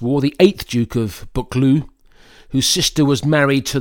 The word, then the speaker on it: Buccleuch: The public house in St. Boswell's is named after the Duke of Becklesh. Actually, it's pronounced Buccleuch, the Duke of Buccleuch.